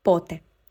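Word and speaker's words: pote (πότε): Be careful, not pote, because if you change the accent and you highlight, you stress the last syllable, then the word changes into a negative word and it means never. So, pote, it's the question word. Pote means when, and pote means never.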